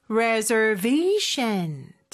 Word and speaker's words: reservation: Reservation. 자, 강세 들리셨죠? 그리고 S 발음은 Z 발음으로 reservation.